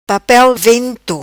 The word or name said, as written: Papel vento